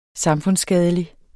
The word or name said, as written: samfundsskadelig